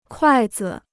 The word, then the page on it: 筷子: kuài zi